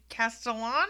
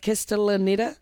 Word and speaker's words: Castellaneta: Dan Castellano? – Castellaneta.